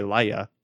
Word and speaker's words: Lyya: No, it is L-Y-Y-A, Laya. I just, like, my brain is like, it has to say Lyra. Nope, Laya.